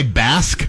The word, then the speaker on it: Basque: This one is, did you say Basque? – Yeah. – Am I saying that correctly? – Yes.